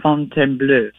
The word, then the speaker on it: Fontainebleau: She was at the original Fontainebleau, and that we're saying it all wrong, Mom. Tell real quick, where is the original, and how do we say it? – The original palace is out just a little bit outside of Paris, and it's called the Fontainebleau.